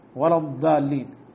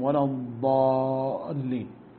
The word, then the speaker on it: الضالين: فبعض الناس يعني يسمع مثل هذا الكلام فيبالغ مبالغة شديدة فيقرأه غير المغضوب بالدال ولا الضالين، يعني أقرب للدال منها للضال. فينبغي أن تنطق النطق الصحيح غير المغضوب عليهم ولا الضالين.